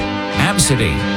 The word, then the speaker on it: ABCDE: People online are sharing names that they can't believe parents actually used, like ABCDE, pronounced Absidy, Archibald, Mulva, and Todd.